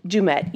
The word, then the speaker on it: Dummett: Dumit, yeah. – How do you spell it? – D-U-M-M-E-T-T.